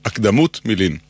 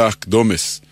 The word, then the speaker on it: הקדמות: חלק מהטעות קשור לשיטת היידיש, שהרי היידיש הדגישה לא את העברה האחרונה, אלא את שלפני האחרונה. לא הקדמות, אלא הקדומס.